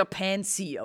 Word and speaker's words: Pansy: Eigentlich wird es ja Pansy ausgesprochen, was übersetzt Stiefmütterchen bedeutet.